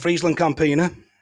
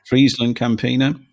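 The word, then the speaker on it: FrieslandCampina: That's how I'm pronouncing it, Friesland Campina. – Friesland Campina.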